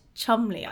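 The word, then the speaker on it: Cholmondeley: Pronounced Chumley, I believe.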